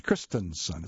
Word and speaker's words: Christensen: Christensen. It's not I-A-N, it's E-N. Christensen is how it's phonetically spelled out.